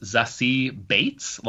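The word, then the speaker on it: Zazie Beetz: So uh, first of all, I, I just learned recently that this actress's name is pronounced Zassie Bates, like, like as in oh, Bates really? Motel.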